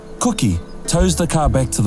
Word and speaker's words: Koki: Janet and Brad discover that their rescuer's name is spelt K-O-K-I, but pronounced Cookie, which he explains to them with a wide smile.